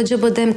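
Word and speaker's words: Acıbadem: Как се чете? – А Джабадем. – Всички му викаме Ачи Бадем. – как не? Турските, турските думи имат винаги ударение на последната het rijk.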